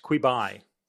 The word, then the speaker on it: Quibi: It's meant to be pronounced Quibi. – Quibi, quick bite, Quibi, I don't know.